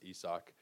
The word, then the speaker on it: Isaac: Isaac Paredes has also been dropped, or I guess Isak. I think it's actually pronounced Isak.